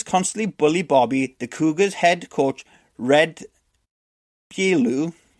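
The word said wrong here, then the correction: Beaulieu: is that how you say it? I've been practicing this. – Red. I know, I had it before. – Bailu. – Bailu, yeah, because it's.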